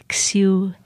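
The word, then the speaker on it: Xiuhtezcatl: It's Xiutescatl, and it's got that TL that is part of the Nahuatl, the original Mexican language.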